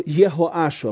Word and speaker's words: Йехоаш: 7 лет было Йоашу, Йехоашу. Обратите внимание, здесь у него он читается как Йехоаш. Если прочитать на иврите, это буква Гей, которая добавлена из имени Всевышнего.